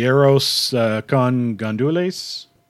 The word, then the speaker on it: arroz con gandules: Another item on the menu I wasn't familiar with, and I'm probably going to butcher the pronunciation, the arroz con gandules. – Yeah, that's perfect. You pronounced that perfect. – All right. – Yeah. So arroz con gandules, traditionally, it's a Puerto Rican rice dish that we would pork in Christmas.